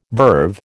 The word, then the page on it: verve: American English